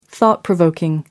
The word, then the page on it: thought-provoking: American English